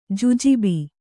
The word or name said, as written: ಜುಜುಬಿ